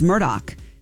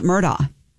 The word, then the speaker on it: Murdaugh: No, that is how you say his name. – Yeah. – It's Murdoch. It's not Murdaugh.